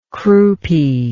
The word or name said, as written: croupiness